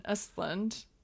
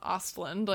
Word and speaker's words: Östlund: How she's the only person who has correctly pronounced Ruben Östlund is how she said it, which is the right way, yeah. – Not Östlund, like how I've been saying it.